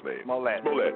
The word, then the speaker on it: Smollett: How do you say his name, Mr. Smollett? Smollett. Is that how you say his name? – Smollett.